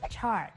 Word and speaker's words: chart: บางครั้งคำว่า chart อาจสร้างความสับสนกับคำว่า charge ที่เรามักจะทับศัพท์คำนี้นะคะซึ่งหมายถึงการเติมพลังแบตเตอรี่หรือการเรียกเก็บค่าบริการนะคะจุดสังเกตก็คือเสียงตอนคำลงท้ายของทั้งสองคำนี้ซึ่งมีความแตกต่างกันนั่นเองนะคะเรามาลองฟังการออกเสียงของสองคำนี้กันอีกครั้งนึงค่ะคำแรกคือ chart ส่วนอีกคำหนึ่งคือ charge พอจะเห็นความแตกต่างในการออกเสียงของทั้งสองคำนี้แล้วนะคะทีนี้มาดูการใช้คำว่า charge ที่แปลว่าเรียกเก็บค่าบริการในประโยคกันค่ะตัวอย่างเช่น Honey, do you realize we get charged almost $100 each month from your subscriptions of websites?